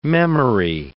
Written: memory

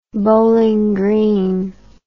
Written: bowling green

